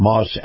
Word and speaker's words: Maus: This portion of the program is brought to you by our friends at Moss Acura. Moss is spelled M-A-U-S, and if you love the feel of a high-end SUV, you're going to love Moss Acura.